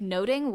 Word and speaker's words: nothing: And a fun fact about the title is, in Shakespeare's day, the word nothing would have been pronounced more like noting, which meant overhearing or gossip.